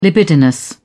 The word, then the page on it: libidinous: British English